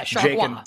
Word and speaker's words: Chargois: Is that really what it is? – No, but it's Chargois. Chargois? – Is it ever like Gua? That would be amazing. – Yeah, Chargois. – Definitely know how to pronounce this guy's name. – I think. How do you pronounce this guy's name, this reliever for the Marlins right now? – It is Chargois.